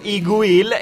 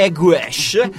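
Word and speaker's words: Y Gwyll: Noi diremmo Simru, ma in gallese è Camry, ovvero la BBC del Galles, il cui nome originale è, noi diremmo, Iguil, e la pronuncia invece è Eguesh, che significa letteralmente The Dusk, ovvero il crepuscolo.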